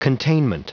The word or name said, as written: containment